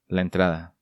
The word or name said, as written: La Entrada